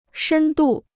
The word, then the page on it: 深度: shēn dù